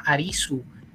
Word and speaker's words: Arisu: él se llama Arisu que se pronuncia como Aris en japonés.